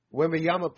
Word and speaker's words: Wembanyama: I thought that would happen because when I saw Wembanyama play, is that how you pronounce it, Wembanyama? – Wembanyama played.